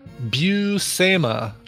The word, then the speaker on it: Buscema: Here's the pronunciation. Buscema.